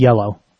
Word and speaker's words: Iello: Iello like the color? – I-E-L-L-O. – Ah, interesting. – Which I believe is pronounced yellow.